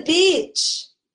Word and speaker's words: beach: So let's learn how to properly pronounce this word so that people can understand what you really want to say. Let's go to the beach. Beach. E. Beach. That is how you say this.